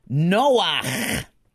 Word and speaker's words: Noach: So instead of cha, the word is pronounced ach. And what word does that come up with right next to you there, right? The first line, Noach. So it's going ach like the musician Bach.